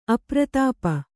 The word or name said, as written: ಅಪ್ರತಾಪ